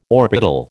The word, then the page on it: orbital: American English